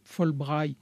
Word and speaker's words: Fulbright: Vous êtes co-président de la commission franco-américaine qui gère les programmes Fulbright.